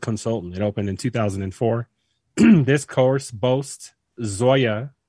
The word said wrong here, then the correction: Zoysia: or how do you say that? Zoya? How do you say that grass? – Does it matter? You gonna say shit anyway. – It don't matter. – Zoysia fairways and bent grass greens.